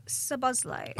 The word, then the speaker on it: Szoboszlai: Well, Dominic Szoboszlai. – Oh, very well pronounced.